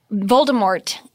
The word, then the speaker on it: Voldemort: But Voldemort doesn't – you don't pronounce the T apparently.